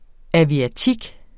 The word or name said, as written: aviatik